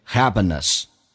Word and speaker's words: happiness: Happiness 这个单词，首先那个 p 浊化成 b，两个元音中间辅音要被浊化，同时那个 n 是发两遍，就变成 happiness，而且那个 a 可以弱化成 a 这个发音，因为它主重音是 a，所以那个 a 可以弱化成 a，就变成 happiness， happiness。